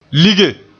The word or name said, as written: lige